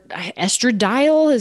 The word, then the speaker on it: estradiol: And it was for estradiol.